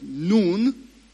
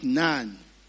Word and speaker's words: Nun: Deuteronomy chapter 34, verse 9, it says that Joshua, the son of Nun, okay, not Nan, okay, Hebrew names and words are supposed to be pronounced not in an English way.